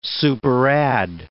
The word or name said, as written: superadd